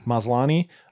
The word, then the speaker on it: Maslany: I'm not going to read all 20 of them, but Snatched, directed by Michael Schwartz, features Tatiana Maslany. Is that how you pronounce it? – Maslany from She-Hulk.